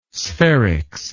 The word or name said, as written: spherics